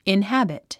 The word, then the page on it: inhabit: inhǽbit; インハビト